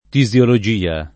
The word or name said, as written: tisiologia